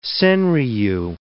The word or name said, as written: senryu